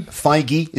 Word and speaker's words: Feige: Is that how Feige? – Is that how you say his name? – Yeah.